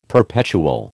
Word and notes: perpetual: American English

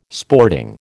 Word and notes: sporting: American English

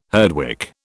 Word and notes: Herdwick: British English

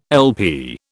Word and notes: LP: British English

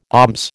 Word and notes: alms: American English

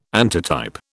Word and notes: antitype: British English

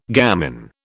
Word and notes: gamin: British English

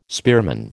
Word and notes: spearman: American English

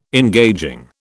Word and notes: engaging: British English

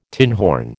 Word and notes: tinhorn: American English

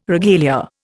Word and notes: regalia: Indian

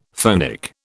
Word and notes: phonic: British English

